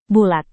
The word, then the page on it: bulat: Indonesian